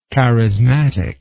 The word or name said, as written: charismatically